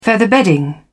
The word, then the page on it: featherbedding: British English